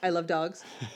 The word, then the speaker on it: Isle of Dogs: Which of you say it right is I love dogs? – I have not.